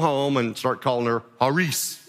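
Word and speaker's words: Haris (χάρις): Our English pronunciation of it is charis. And it's fine to say that, but that's really not how it's pronounced. The C in Greek is silent. And so this word is actually pronounced Haris. Haris. The I would be like a long E.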